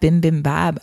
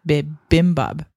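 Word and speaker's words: bibimbap: There's no M on the first one, so it's like bim bim bab.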